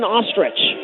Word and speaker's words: Ostrich: It's number 21, Logan Ostrich. Her name is not spelled like the bird, but just pronounced like it.